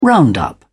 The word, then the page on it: round up: British English